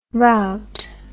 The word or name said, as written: rowte